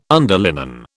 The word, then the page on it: underlinen: British English